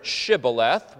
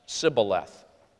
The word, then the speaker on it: shibboleth: were able to pronounce this word, shibboleth, whereas those on the west side of the Jordan, you know, the promised land in terms of, you know, its literal application of that term, the west side of the Jordan, they had lost this consonant and could only pronounce it with a simple S, sibboleth.